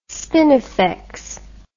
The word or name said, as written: spinifex